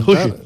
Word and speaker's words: Juche: Que lo ha pronunciado mal. Juche.